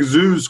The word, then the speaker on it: zhuzh: The word is zoos, and that is spelled Z-H-U-Z-H. – Z-H-U-Z-H.